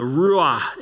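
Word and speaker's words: Ruah (רוח): Darkness covered the surface of the watery depths, and the Spirit of God, the Ruah in Hebrew, that's how you say it, was hovering over the surface of the waters.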